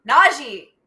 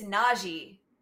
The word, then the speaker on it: Najee: Najee, by the way, announcers, that's how you pronounce his name. – Najee. I'm like, Najee. – I understand like in French, two E's can be A. He has said several times, it's Najee, how it's spelled.